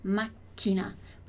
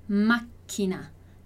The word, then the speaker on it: macchina: Então, máquina. Porque tem dois Cs ali, a gente para um pouquinho na pronúncia, a gente demora um pouquinho mais ali. Então, máquina, máquina, que é o carro.